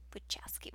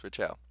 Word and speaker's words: Wachowski: Yes, Wachowski. – Yes, there's a ski on the end. – Yeah, there is. Wachow. – It's not just Wachow.